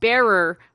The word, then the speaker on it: Bearer: I always thought that they were called Barra Bonds. And... But they're called Bearer Bonds.